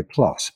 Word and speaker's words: plus: you say plus, pronounced that way, plus, P L U S.